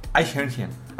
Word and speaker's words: Eichhörnchen: Eichhörnchen. – Eichhörnchen. – Eichhörnchen. – Eichhörnchen. – Eichhörnchen. Güey, 6 meses después. – Madita. Eichhörnchen. – Pero a ver, entonces, ¿cómo se pronuncia? – Eichhörnchen.